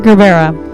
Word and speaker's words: Gerbera: Is it Gerbera? I don't know how to pronounce it. – Gerbera.